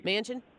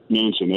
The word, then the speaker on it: Manchin: Was it Munchin? – Manchin? – Manchin, there we go.